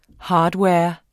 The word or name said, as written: hardware